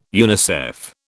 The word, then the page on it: UNICEF: British English